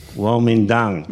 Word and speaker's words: Kuomintang: And we had the rise of, now, how would you pronounce the, well, Chiang Kai-shek and the Kuomintang? – Kuomintang. Yes. – Okay. It doesn't look like that on my screen.